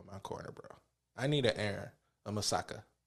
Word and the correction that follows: Mikasa: Did I say that right? – Mikasa. – Mikasa.